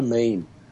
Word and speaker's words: meme: But as I say, the internet has gone absolutely crazy with memes because we love a meme. – I'm glad you say it properly. I've heard people muller that word. They call it memes. – Oh, really? – No. It's a meme like a theme.